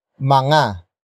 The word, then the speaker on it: mga: But before that we have mga. Mga. Mga. Mga is spelled as M-G-A.